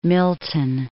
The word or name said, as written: Milton